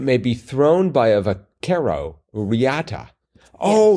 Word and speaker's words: vaquero: Vaquero.